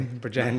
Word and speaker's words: Bridgend: I'm from, you said it correctly, Bridgend, nice.